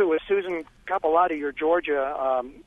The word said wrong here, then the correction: Capiluto: I mispronounced it a couple of times, too, but it's Capiluto.